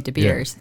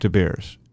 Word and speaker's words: De Beers: How do you pronounce De Beers? – No, you pronounce it right, De Beers. – Yeah, De Beers.